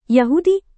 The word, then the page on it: yahudi: Indonesian